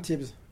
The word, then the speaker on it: Tibs: No, I'm Tibs. – Tibs. Yeah. Tibs Parise. Is that right? – Yep, you said it right.